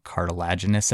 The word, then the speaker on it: cartilaginous: There's a word for that, but I always say it wrong. Cartilaginous, I think, is how you say it.